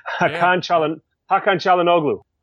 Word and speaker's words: Hakan Çalhanoğlu: Hakan Çalhanoğlu.